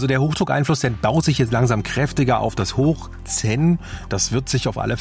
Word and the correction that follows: Zeno: Von Westen her bringt es dann auch richtig, nee, Zeno, jetzt habe ich falsch gesagt, Zeno heißt der.